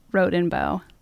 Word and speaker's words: Rodenbough: Well, I can put all the rumors to rest right now and tell everyone that it's pronounced Rodenbough.